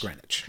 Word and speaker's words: Greenwich: It's spelled Greenwich, yeah, but it's Greenwich. Pronounced Greenwich.